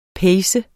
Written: PACENDE

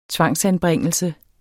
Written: tvangsanbringelse

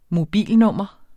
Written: mobilnummer

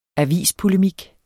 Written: avispolemik

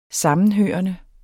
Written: sammenhørende

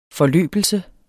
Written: forløbelse

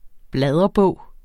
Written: bladrebog

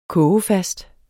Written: kogefast

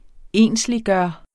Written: ensliggøre

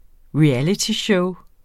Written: realityshow

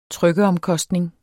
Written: trykkeomkostning

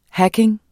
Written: hacking

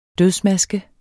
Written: dødsmaske

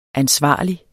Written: ansvarlig

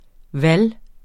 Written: VALGET

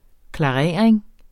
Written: klarering

